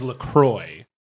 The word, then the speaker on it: LaCroix: Pronunciation is LaCroix.